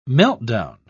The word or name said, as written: meltdown